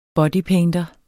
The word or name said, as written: bodypainter